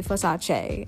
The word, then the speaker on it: Versace: So right now, so far, Versace, and should I say Versace, as she wanted us to pronounce it correctly, Donatella, and Givenchy.